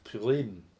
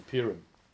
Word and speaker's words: Purim: And this event, as we were talking about last week, is memorialized in Jewish history by the feast of Purim, as it's pronounced, or Purim, to us non-Jews.